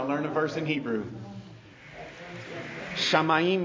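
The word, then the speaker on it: Shamayim (שמים): Shamayim.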